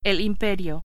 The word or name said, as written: imperio